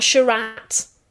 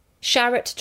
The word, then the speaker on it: Sharratt: Now, just in case you're wondering, Sharratt, he does say on his website, it's not Sharratt. Sharratt to rhyme with carrot or parrot.